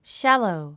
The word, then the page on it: shallow: ʃǽlou; シャァロォ